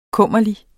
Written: kummerlig

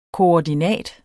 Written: koordinat

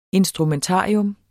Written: instrumentarium

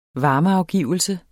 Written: varmeafgivelse

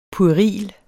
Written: PUERILT

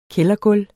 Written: kældergulv

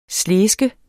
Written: SLESKES

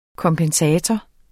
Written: kompensator

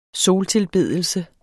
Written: soltilbedelse

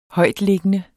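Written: HØJTLIGGENDE

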